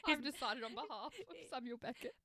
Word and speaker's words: Samuel Beckett: And the playwright is none other than Samuel Beckett. I'm saying that right, am I? – Yeah. – Samuel Beckett.